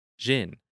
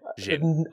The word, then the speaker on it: Xin: X-I-N. – Okay. Xin. I would have never fucking guessed that in a million years. – Yeah, X-I-N. So I mean, I could be butchering that, but I believe it's Xin.